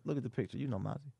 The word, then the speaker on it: Mozzy: Mozzie? – Yeah. – How you spell that? – M-O-Z-Z-Y. – M-O-Z-Z-Y. I feel like I should know that. – You know Mozzie.